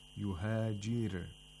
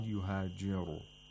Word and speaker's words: يُهَاجِرْ: يُهَاجِرْ, the ra is pronounced with tarqiq because the ra is sakin and is preceded by a letter with the kasra. So we have to pronounce the ra with tarqiq, with thinness. Yuhajir, not yuhajiru.